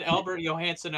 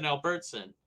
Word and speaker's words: Albert Johansson: Jared here is throwing me under the bus, reminding us how I said Albert Johansson Albertson. Now, that is totally different. That is pronunciation.